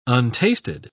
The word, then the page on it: untasted: British English